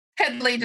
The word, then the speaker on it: Headlee: Headlee, just like it's spelled.